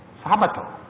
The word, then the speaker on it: Sabato: Sabato. It's the Sabbath. It is spelled in Spanish with a D and in Italian with a T.